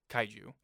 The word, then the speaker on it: kaiju: It's like if you join the organization, this is the pamphlet that's given to you of how to handle kaiju.